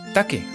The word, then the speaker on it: take: T, T t, tetero, A, K, E. Taque, así como el taqui taqui, pero con E.